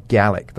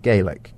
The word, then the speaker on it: Gaelic: Yes, Gaelic. That's how it's pronounced, not Gaelic. Gaelic is Irish.